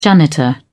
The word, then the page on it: janitor: British English